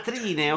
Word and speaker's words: Trine: Train, Trine. – Ah, Trine, ok, sì.